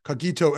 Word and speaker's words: cogito: Thank you for that, Sarah, as cogito, as in get. It's a hard G. Cogito ergo sum.